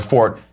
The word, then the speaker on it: forte: If you want to be perfectly and impeachably correct, you will pronounce the word fort, meaning something that is one strong point, ideally to the word, identically to the word fort, and reserve for forte, pronounced only for the musical term.